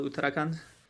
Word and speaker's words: Uttarakhand: See is working in a rural part of India that is south of Tibet called the, say it for me, so I say it right. – The city's name is Dehradun and the state is called Uttarakhand.